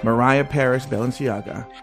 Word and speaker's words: Mariah Paris Balenciaga: Well, first of all, thank you for saying her name correctly. Mariah Paris Balenciaga.